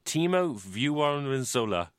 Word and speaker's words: Timo Vuorensola: Timo Vuorensola.